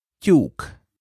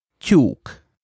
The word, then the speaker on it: tyúk: Sure. Tyúk. Tyúk. – Which means hen. – Say that a few times, everyone. Tyúk. Tyúk. – This sound is spelled T-Y.